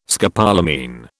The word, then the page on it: scopolamine: British English